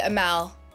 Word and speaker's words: Amaal: It's actually the X is silent, so it's Nuh, but you could just say Amaal.